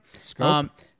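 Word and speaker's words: Schoop: They signed Scoop. Am I saying that right? – Scoop.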